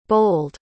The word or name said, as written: bowled